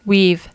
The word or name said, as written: weave